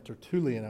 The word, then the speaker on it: Tertullian: Tertullian, I believe is how you say it.